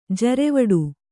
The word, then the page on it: ಜರೆವಡು: Kannada